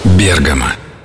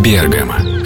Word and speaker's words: Бергамо: Бергамо, Италия. Да, именно так говорить правильно. Бергамо.